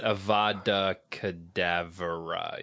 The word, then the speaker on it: Avada Kedavra: It's Avada Kedavra, yeah.